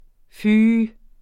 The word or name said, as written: fy